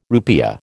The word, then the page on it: rupiah: American English